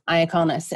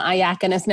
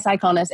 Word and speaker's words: Iaconis: I always want to call him Iaconis now because my telephone calls him, you know, my phone pronounces his name phonetically. But one of these days I'm going to literally say his name wrong. But it's Tobias Iaconis.